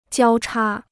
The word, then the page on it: 交叉: jiāo chā